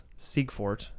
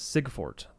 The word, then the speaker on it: Siegfort: So it's IE. – Mm-hmm. – And throughout history, IE is supposed to make the E sound. – Mm-hmm. – And I've, to my knowledge, been the first person in my family to pronounce it like that. Because everybody says Siegfort. Like there's no E.